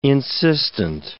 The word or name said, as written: insistent